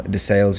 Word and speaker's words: DeSales: She holds a bachelor's degree in philosophy and biology from DeSales University.